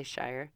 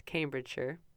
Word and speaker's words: Cambridgeshire: My British friend told me how to say it. I always want to say Shire. It's Cambridgeshire. Close. I mean, that's as close as I can get.